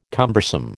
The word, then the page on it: cumbersome: American English